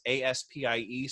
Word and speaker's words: Aspie: But for those watching, the space ASPIE space world, it's pronounced Aspie, and it's an excellent resource.